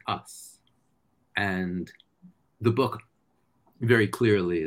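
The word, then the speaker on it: us (ус): And I call these species collectively, first I'll spell it, YC. And it's pronounced us.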